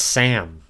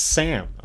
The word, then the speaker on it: SNMM: And this time they went to Redbird Esports for that with it's pronounced Sam. Okay. It's spelled SNMM, but it is pronounced Sam, apparently.